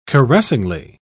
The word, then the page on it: caressingly: British English